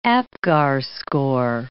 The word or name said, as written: Apgar score